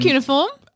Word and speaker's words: cuneiform: Cuneiform? – Cuneiform.